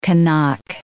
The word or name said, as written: Kanak